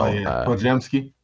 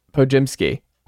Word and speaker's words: Podziemski: And then you have two interesting picks in Brandon Podziemski. I don't know how to pronounce his name yet. We'll wait until... – Podziemski. – Podziemski.